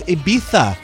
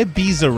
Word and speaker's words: Ibiza: It's Ibiza not Ibiza, Ryan. Ibiza.